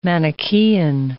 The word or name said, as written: Manichaean